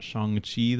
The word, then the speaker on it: Shang-Chi: We are going to be talking about Shang-Chi this week. That's how it's pronounced. – Yeah, I looked it up multiple times. You just got to think like there's an H after the A, and that's how it helps me.